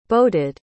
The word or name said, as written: boated